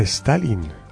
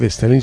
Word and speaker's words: Stalin: Y descubrió desde los 90 varios de los enterramientos de numerosas víctimas de la dictadura de, no se dice Joseph Stalin, sino Joseph Stalin.